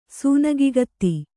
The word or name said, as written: ಸೂನಗಿಗತ್ತಿ